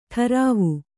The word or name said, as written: ಠರಾವು